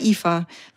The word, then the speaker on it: Aoife: Mich fragt man in Irland immer, wie Eva mit IWE oder, weil es gibt ein gälisches Eva und das schreibt man mit A-O-I-F-E. – Na, A-O-I-F-E. Und das heißt, sagt man aber Eva, weil alles bis zum I bleibt stumm.